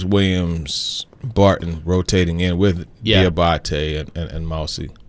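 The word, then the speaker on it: Diabaté: Yeah. Mohamed Diabate. – Yeah. – Yes. – Diabate.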